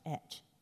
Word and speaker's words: ate: Now, eight is pronounced et.